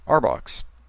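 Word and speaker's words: RBOC: Then there are the regional Bell operating companies, or RBOCs, pronounced Arbox.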